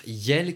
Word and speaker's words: iel: Le pronom personnel neutre « yel », qui est donc le mélange de « il » et de « elle » et qui s'écrit « i-e-l », a fait son entrée pour la première fois dans un dictionnaire, en l'occurrence le dictionnaire Le Robert.